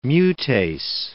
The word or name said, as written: mutase